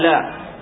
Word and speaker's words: لا: فنطقها مثل نطق حرف النفي المعروف لا، حرف النفي المعروف الذي هو لا.